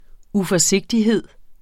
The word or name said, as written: uforsigtighed